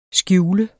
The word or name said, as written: skjuler